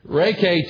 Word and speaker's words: Reiki: It's spelled R-E-I-K-I, but it's pronounced Reiki.